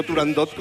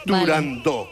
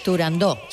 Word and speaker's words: Turandot: pues yo también lo digo mal y digo Turandot como todo el mundo. Pero para que quede claro... – Entonces todos tontos ya. – Para que quede claro, es Turandot. Vale. – Turandot.